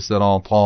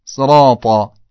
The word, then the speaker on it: صراط: به جای که بگید طا ا بگید، بگید طا به صورت الف بگید، متالف رو نکشید که بشه صراط، اونجوری نه، صراط.